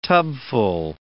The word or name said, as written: tubful